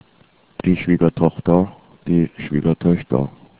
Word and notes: schwiegertochter: german

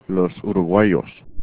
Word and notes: uruguayos: spanish

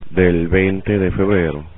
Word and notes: del veinte de febrero: spanish

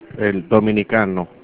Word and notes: dominicano: Spanish

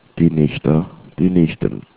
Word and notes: nichte: german

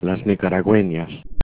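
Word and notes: nicaragueñas: Spanish

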